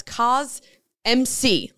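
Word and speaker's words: CosMc's: And yes, that's cos MC, like Mick from McDonald's. – Okay, but it's it, it's pronounced like the word cosmic.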